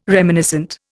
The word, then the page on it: reminiscent: Indian